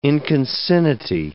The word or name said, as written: inconcinnity